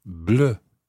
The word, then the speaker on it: bleu: N-O-I-R-E. But un livre noir. N-O-I-R. Exactly the same happens with bleu. That's spelled B-L-E-U. Don't get that mixed up, because if you spell it in English, then it won't look right. Bleu. B-L-E-U. So, un livre bleu, a blue book, B-L-E-U, une voiture bleue.